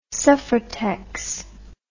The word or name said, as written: suffrutex